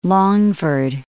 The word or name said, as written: Longford